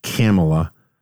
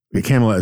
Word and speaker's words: Kamala: But I seem to recall it's Kamala. Kamala as in rhyming with Pamela.